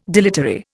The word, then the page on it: dilatory: Indian